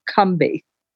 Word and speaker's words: Combahee: which I've recently found out is accurately pronounced Combahee. So we've been saying it incorrectly all these years.